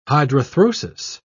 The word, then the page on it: hydrarthrosis: British English